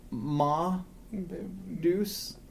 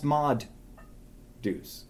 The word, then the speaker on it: Ma Deuce: It's not pronounced ma deuce. It's pronounced mod deuce. It's M2.